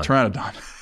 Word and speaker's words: Pteranodon: All right, AJ Brown is the, uh, I can't even pronounce it, Terra. Pteranodon. – Pteranodon. – There you go.